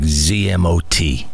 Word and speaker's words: ZMOT: I don't know if can we say that ZMOT or what should we how should we say that? – I just like ZMOT. – Okay, well, we'll keep using that ZMOT.